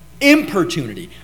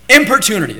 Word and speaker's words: importunity: I didn't say opportunity. Importunity. I M P O R T U I T U N I T. Why? Don't go by my spelling. Importunity. That's the word. I'm pronouncing it right.